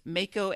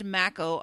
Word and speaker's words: mako: Um, I went on a little hunt, and either way is fine, as Jane Straus, the grammar lady, used to say. It's Mako and Mako are equally correct the like pronouncer on the Oxford Dictionary is Mako, and the pronouncer on the Merriam-Webster is Mako. So, eh, and, the although, pronouncer on the Pete Rhodes website is Mako.